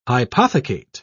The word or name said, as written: hypothecate